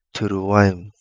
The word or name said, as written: Turuaimu